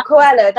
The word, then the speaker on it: Coelho: There we go, Coelho, that's it.